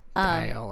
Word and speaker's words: Dial: Mindset Mentor with Rob Dial. I think that's how you say his name. – Dial, like the soap.